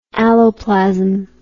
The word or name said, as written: alloplasm